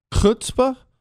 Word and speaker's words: chutzpah: Chutzpah? Did I say that right? I did? That's closer. Oh, that was right.